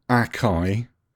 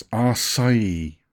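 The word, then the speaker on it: açaí: It's not acai. It's acai. Okay. You're right. Google proves it. Acai.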